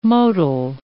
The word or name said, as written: motile